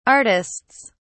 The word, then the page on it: artists: British English